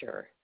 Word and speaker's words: Schurr: Deborah Schurer. – Deborah, will you spell that last name for me, please? – S-C-H-U-R-R.